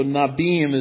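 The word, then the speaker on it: Nevi'im: So Nabim is really the way it's pronounced as far as I understand.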